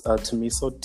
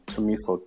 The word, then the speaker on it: Thumiso: So it's Tamisha, T-H-U-M-I-S-O. Just Google me, Tamisha, T-H-U-M-I-S-O.